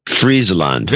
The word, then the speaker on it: Friesland: Friesland.